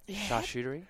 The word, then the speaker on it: charcuterie: A good charcuterie. How do you say that? – Charcuterie. – Charcuterie board? – I think that's how it is.